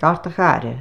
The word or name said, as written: GARTACHARRA